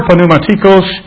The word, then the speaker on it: pneumatikos: It's a word, pneumatikos. In the Greek, you pronounce the P.